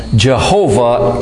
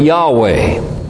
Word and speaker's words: Jehovah: And I should say, just in passing, Jehovah is the proper way to pronounce that Hebrew name. Modernists and those who are perhaps a little ignorant of the history of it will sometimes speak about Yahweh.